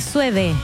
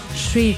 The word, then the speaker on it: Suede: Oye, Sweet se dice, ¿sabes a qué me refiero? ¿Cierto? Se escribe Suede. Hay gente que dice Sued, pero es Sweet, Sweet.